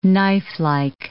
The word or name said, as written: knifelike